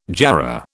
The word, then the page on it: jarrah: British English